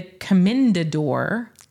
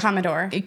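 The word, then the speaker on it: Comendador: It was apparently the Commendador wine. – How did we say it? Commodore. – Commodore. We butchered it, but it was quite lovely.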